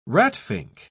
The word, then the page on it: ratfink: British English